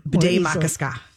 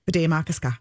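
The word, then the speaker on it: Bde Maka Ska: Bede Makaska. – Ooh, do it again. – Bede Makaska. – Bede Makaska.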